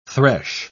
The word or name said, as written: thresh